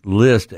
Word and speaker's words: Licht: List, which is L I C H T, but it's pronounced List,